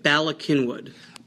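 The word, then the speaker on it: Bala Cynwyd: Bala-Cynwyd.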